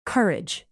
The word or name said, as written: courage